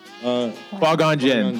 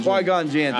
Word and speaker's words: Qui-Gon Jinn: Qui-Gon Jinn. – Qui-Gon Jinn, thank you.